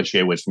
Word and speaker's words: Shaywitz: Russell combined his brain research in overcoming dyslexia by Sally Shaywitz. I said her name right, right? – Yep, that's Dr. Sally Shaywitz from Yale.